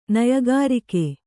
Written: ನಯಗಾರಿಕೆ

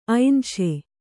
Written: ಐನ್‍ಷೆ

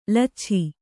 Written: ಲುಚ್ಛಾ

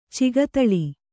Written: ಚಗತಳಿ

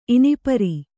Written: ಇನಿಪರಿ

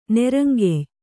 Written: ನೆರಂಗೆಯ್

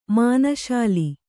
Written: ಮನಶ್ಶಿಲೆ

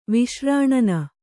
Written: ವಿಶ್ರಾಣನ

